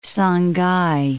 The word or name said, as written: Sangay